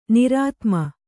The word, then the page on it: ನಿರ್ಧೂಮ: nirdhūma